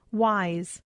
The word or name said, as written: wise